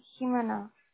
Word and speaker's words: Jimena: Yes, it's Jimena.